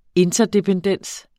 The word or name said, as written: interdependens